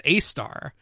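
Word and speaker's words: Sagittarius A*: Well, star number three, sad to say, is Sagittarius A-star, which it's pronounced A-star, but it actually refers to the supermassive black hole at the center of the Milky Way galaxy.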